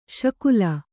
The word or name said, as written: ಶಕಲಿ